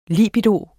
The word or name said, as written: libido